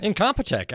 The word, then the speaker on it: Incompetech: Kevin McLeod is a man who is the owner, creator of, I might not be pronouncing this right, Incompetech? – Incompetech, absolutely. – Nailed it, first try.